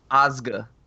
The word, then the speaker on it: Ozge: Ozga. – Oh, okay. – O-Z-G-E. Yeah, Ozga Perkins, and she's a Black Coat's daughter.